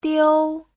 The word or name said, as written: diu1